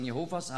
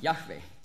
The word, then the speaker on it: Jahwe: Die Zeugen Jehovas haben an sehr vielen Stellen haben sie Jehova eingesetzt, obwohl eben im Grundtext nie Jehova steht und auch nicht Jahwe, die richtige Aussprache, sondern Kyrios.